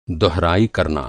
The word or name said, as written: दोहराई